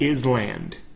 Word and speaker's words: island: It looks like island. Island, because there's an S. It's I-S-L-A-N-D. Right? But the S is silent, so it's just island.